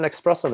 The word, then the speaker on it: espresso: We got an espresso maker. – Just for the viewers out there, everybody pronounces it this way, but there's no X in that word.